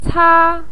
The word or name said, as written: ca1